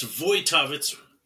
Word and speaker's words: Wojtowicz: It's Chris Wojtowicz, pronounced with a V. – See, but, look at that. – But not spelled like that, spelled with a W.